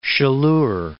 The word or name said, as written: Chaleur Bay